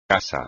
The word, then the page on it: casa: espagnol